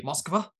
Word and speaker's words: Moskva: Moskva, that's how they say it.